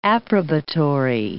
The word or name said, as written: approbatory